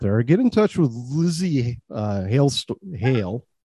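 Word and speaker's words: Lzzy Hale: Lizzie Hale, and her first name is spelled L-Z-Z-Y. See how she did that? – Right. Yeah, without the vowel in there. So Lizzie Hale.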